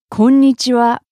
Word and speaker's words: konnichiwa: Konnichiwa.